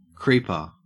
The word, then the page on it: creeper: Australian English